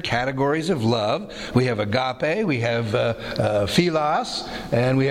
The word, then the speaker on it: agape: It just needs a longer tail, and so they think of the E as being... almost an H sound, eta, and so it is agape. Sometimes people don't realize what that thing is. So it's actually an E pronounced as an A, but it looks like an H, and that's how I remembered it first year Greek.